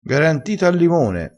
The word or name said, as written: garantito